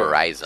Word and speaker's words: Horizon: Olha aí, tá certo essa pronúncia aí, Jô? Horizon. – Tá, tá é. certo, tá certo.